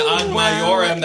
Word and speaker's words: Ad Majorem: Ad Majorem, that's how I would say it. Ad Majorem De Gloriam, which means in our English, come on, she mispronounced it. – All for the greater glory of God. For the greater glory of God. – I just gave a different sound to the J.